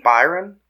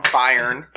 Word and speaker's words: Bayern: Byron? – No. – Bayern.